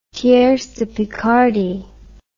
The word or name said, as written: tierce de picardie